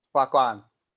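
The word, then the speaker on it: wagwan: You say it like wag one. – Wag guan.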